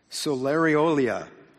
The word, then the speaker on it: Soleirolia: S-O-L-E-I-R-O-L-I-A. Anybody got it? Solariolia.